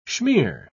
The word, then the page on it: schmeer: British English